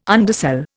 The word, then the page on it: undersell: Indian